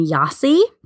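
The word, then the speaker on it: Gyasi: It's by I believe you say her last name, Yasi. I think the G is silent. It's G-Y-A-S-I.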